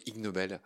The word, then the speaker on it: Ig Nobel: Je voulais m'attarder un peu sur le prix Ig Nobel. Donc ça s'écrit IG Nobel, donc il y a un jeu de mots sur le fait que ce soit ignoble.